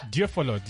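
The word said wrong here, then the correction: Deulofeu: How do you say? – Delofeo. – Sorry, my bad.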